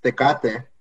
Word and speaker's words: Tecate: It's pronounced Tecate.